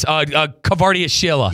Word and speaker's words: Kvaratskhelia: Kvaratskhelia. – Yes. – Oh, my God. – I'm glad you pronounced his name. – Yeah, Kvaratskhelia.